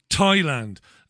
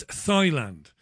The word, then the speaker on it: Thailand: It's Thailand, not Thailand. I used to say Thailand. I could never understand why people would giggle at me. It's Thailand.